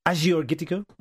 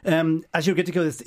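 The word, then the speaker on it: Agiorgitiko: So Gaia is the producer and it's Azure Gittico. I checked this on the internet today. – Okay, well done. – I've been saying, I've been giving it a hard G. It's a soft G, which means St. George, and it's from Nemea, which is arguably the best wine region in Greece.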